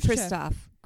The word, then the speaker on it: Khrushchev: A built-in remedy for, I can't say his name, Khrushchev? – Yep. – Yeah? Mm-hmm. Okay. – Sure. – And Kennedy. – Khrushchev? – Khrushchev. I always hear Khrushchev.